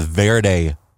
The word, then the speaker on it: Verde: So apparently this area is not called the Verde Valley. – It's not called the Green Valley? – Even though it's spelled V-E-R-D-E, like the Spanish word for green. – Yes. – And the name is because it's green along the river here. It's the Verde River. Everybody here calls it Verde, apparently, and we've been saying it wrong.